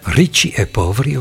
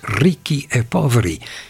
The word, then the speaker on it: Ricci e Poveri: Ik wil niet de bedweter uithangen, maar ik hoor de naam van deze groep vaak fout uitspreken. Ricci e Poveri of zo. Maar het is wel degelijk in het Italiaans Ricci e Poveri.